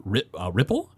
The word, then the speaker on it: Riepl: Yeah, it was composed by Kevin Ripple, I think is what his name is pronounced, R-I-E-P-L.